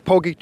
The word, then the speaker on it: Pogi: So you are the, is it the Poggi team or the Poggi team? How do you say that? – It's Poggi team.